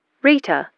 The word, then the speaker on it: Rita: K-A-T-Z. – And what's your first name? – Rita. R-I-T-A.